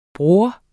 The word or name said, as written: bror